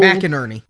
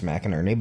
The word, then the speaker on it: McInerney: McInerney. – Yeah, yeah, I think it's Innery though. I think that is how you pronounce it. I've known many people who spell this name that way, and it's McInerney.